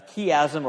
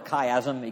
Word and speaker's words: chiasm: Something that's a little more hard to find is what Ben introduced us to this last week in his sermon, a chiasm or a chiasm. It can be pronounced either way.